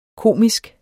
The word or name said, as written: komisk